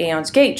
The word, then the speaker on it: Aeon's Gate: Am I even pronouncing that right? – Yeah, that's right. – Yes! For once I've gotten the pronunciation. So I didn't have a chance to read Aeon's Gate just because of availability up in Canada.